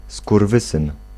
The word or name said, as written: fils de pute